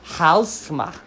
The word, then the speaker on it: Halsema: F-E-M-K-E. Femke. Halsema. H-A-L-S-E-M-A.